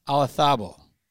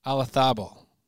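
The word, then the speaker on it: Olazábal: Patrick Reed and Jordan Spieth, Tom Kite and Curtis Strange, Justin Rose and Henrik Stenson, and Seve Ballesteros and Jose Marie Olazabal, even though it's with a Z. I think they pronounce it Thabal.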